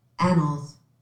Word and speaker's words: annals: The anals in that phrase means a chronological record of events. – Oh, anals. – Oh. – It's pronounced differently, apparently.